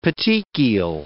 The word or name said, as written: petechial